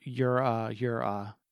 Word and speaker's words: Yura Yura: It's pronounced that because that's in Japan or Japanese. It would be Yura Yura.